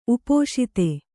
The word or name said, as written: ಉಪಸ್ಥ